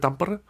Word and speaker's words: Tampere: Sagt der Finne Tampere? – Mhm. Wahrscheinlich. Auf jeden Fall wird auf der ersten Silbe betont, das habe ich gelernt.